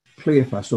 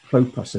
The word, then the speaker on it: Clopas: Mary, the wife of Cleophas, or Clopas, as it would be better pronounced.